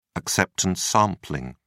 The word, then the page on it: acceptance sampling: British English